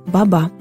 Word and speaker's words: баба: Кажется, в тот самый миг мы с мужем говорили о свекрови, и Настя нежно назвала ее «баба». Первым словом дочери было не папа и не мама, а именно «баба» с ударением на втором слоге.